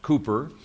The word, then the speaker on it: Cowper: There are even godly Christians like William Cowper, whose name is actually, I guess, pronounced Cooper, but it's spelled Cowper, who have either attempted suicide or have been successful in committing suicide.